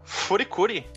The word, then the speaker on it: Furikuri: que é um anime da Gainax chamado de Furikuri. – Furikuri. – Furikuri. F, você vou encontrar ele como Furikuri por extenso ou FLCL, como ele é mais é, conhecido, porque Furikuri seria a pronúncia japonesa para FLCL, ou então no release americano dele escrito Fulikuli, com uhum.